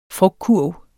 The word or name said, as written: frugtkurv